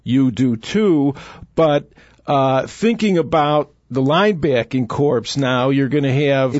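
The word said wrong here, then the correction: corps: It's core. The P is silent, Boss. – Okay. – A corpse is a body that, like habeas corpus, okay?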